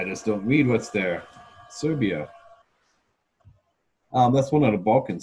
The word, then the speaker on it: Serbia: And then you want, then I did, I tried Siberia. – Then you Serbia. Yep. Sorry, Serbia. I should say that right. Think of Russia, Siberia.